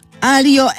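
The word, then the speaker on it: aglio: Tak przy okazji są i tacy, którzy mówią tagliatelle, więc warto poznać włoską wymowę kolejnej zbitki GL, w której G się nie wymawia, a to się przyda przy okazji innego dania alio e olio, czyli makaronu z oliwą i czosnkiem oraz lasagne.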